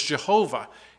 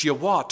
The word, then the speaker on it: Yahweh: Mispronunciation of some people is Jehovah. In Hebrew, there is no J sound. It's Yahweh.